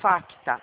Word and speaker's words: FACTA: de faca, A de amor, C de casa. T de tatu, A de amor. Facta. O C de casa é mudo.